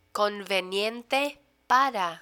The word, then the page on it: conveniente para: Spanish